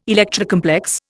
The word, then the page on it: Electra complex: Indian